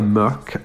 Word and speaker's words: Mørk: Now we continue the pronunciation problems once more, because this week we're speaking to Norwegian-Russian singer-songwriter Maria Mørk. I think that's how you say it. M-O with a line through it. You've seen that before. It's a Norwegian character. R-K, Mørk.